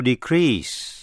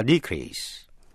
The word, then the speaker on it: decrease: Nhận xét cách phát âm, động từ to increase, danh từ an increase, động từ to decrease, danh từ a decrease. Vậy động từ nhấn mạnh vần nhì, danh từ thì nhấn mạnh vần đầu trong trường hợp này.